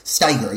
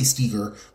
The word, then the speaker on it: Steiger: Steiger. Yeah, I was going to say Steger. That's the Cleve the sharp Cleveland syllable.